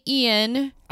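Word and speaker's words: Iain: It is based on the book by, I'm going to say Ian. – I'm going to agree with you. – Okay, because it's not spelled like Ian's normally spelled. It's I-A-I-N.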